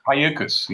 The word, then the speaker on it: Cayucos: Cayucos? – Cayucos, yeah.